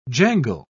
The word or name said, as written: jangle